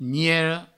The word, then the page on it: niera: niero